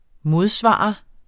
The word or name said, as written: modsvar